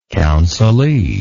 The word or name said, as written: counselee